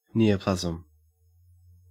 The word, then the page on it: neoplasm: Australian English